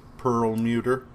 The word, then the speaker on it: Perlmutter: So, I mean, bearing in mind that Marvel has been run for some time by Ike Perlmutter, I don't know how to say his last name. – Perlmutter. Yeah.